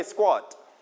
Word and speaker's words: squat: Everybody repeat after me, squat. Okay, squat. In English, we pronounce k as g when it is with s.